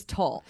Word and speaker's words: Tolle: Is it Tolle or Tolle? We should know this. – It's Tolle. Eckhart Tolle. – Okay. I've been saying it wrong.